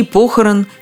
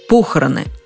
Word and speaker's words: похороны: В именительном падеже ударение ставится на первом слоге – похороны, а во всех остальных падежах – на окончании – похорон, похоронам, похоронами, о похоронах. Но смущает всех именительный падеж.